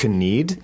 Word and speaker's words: knead: It looks like knead, but the K is silent. You're massage, like mixing in a slow massage way. – Right, yeah. And it sounds the same as knead, like I need a pie. – Exactly. – Meaning I want a pie. – And knead is spelt K-N-E-A-D.